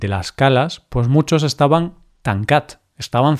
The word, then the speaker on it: Tancat: Tú sabes catalán, entonces si te digo Tancat, ¿sabes lo que significa? Qué buena pronunciación.